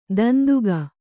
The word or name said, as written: ದಂತಿಗ